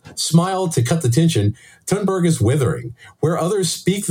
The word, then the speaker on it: Thunberg: By the way, she, I would like to take a moment here to compliment your pronunciation of Thunberg.